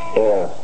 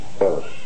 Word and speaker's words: err: That's what you breathe, air. But this word is pronounced er. What? Yes, er. To err is human. Pope wrote that some years ago. Remember, to err is human, to forgive divine, not to err. If that's the way you pronounce it, you erred. Nice. So it's erring, erred, but it is error. Runs, hits, and errors. Okay, that's the only time that's pronounced that way. Otherwise, it's err.